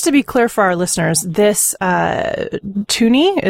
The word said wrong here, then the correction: Tunny: is that the pronunciation correct? Uh, Tuni or Tunny? – Tunny? Tunny.